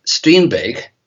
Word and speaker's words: Strindberg: And in the Occult Diary, I, I used Strindberg, which is how they pronounce his name here,